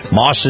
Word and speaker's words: Maus: Moss is spelled M-A-U-S, Moss Family Automotive.